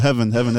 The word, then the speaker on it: HVN: Almost to But Heaven, that's a capital HVN. That's how you spell it. Nice. Heaven.